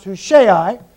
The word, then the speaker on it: Hushai: Now, there's one individual, his name is, I believe it's pronounced Hushai, and he comes to David and he wants to flee with David.